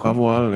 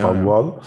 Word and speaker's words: Paviol: Da hat es auch weiter französisch sprechende Lifterin, eine Französin selber, Lia Paviol, ist das richtig ausgesprochen? – Paviol, ja, nicht mehr. – Paviol.